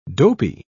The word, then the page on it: dopy: British English